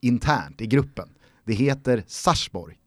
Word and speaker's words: Sarpsborg: att en eh, tittare skrev liksom så här öppet till eh, Eurosport och Kanal 9-gänget att eh, varför i hela fridens namn säger ni Sarpsborg när det på norska uttalas Sarsborg, alltså med ett stumt P.